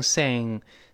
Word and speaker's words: sing: 哎，舌根拱上去，把喉咙堵死了。 n n sing tonight 就是今天晚上。